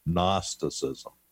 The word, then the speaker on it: Gnosticism: Gnosticism. I hate Gnosticism. It's even spelled with a silent G, which is annoying.